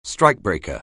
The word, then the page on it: strikebreaker: anglais